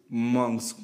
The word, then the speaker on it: Musk: Mm, Musk, ¿cómo es? – Musk. – Musk.